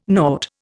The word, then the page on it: nought: Indian